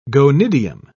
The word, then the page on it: gonidium: British English